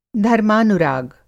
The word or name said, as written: धर्मानुराग